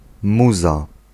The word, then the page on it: bakbanaan: /ˈbɑɡbaˌnaːn/